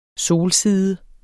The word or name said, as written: solside